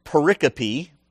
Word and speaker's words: pericope: There's a Greek word that is pericope. Pericope, okay? It's spelled pericope, P-E-R-I-C-O-P-E, that means a passage that is suitable for reading aloud.